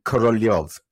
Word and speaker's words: Korolev: She kept calling him Korolev. He's spelled K-O-R-O-L-E-V, but K-O-R-L-Y-O-V is the way I see it in my brain. That's how I pronounce him.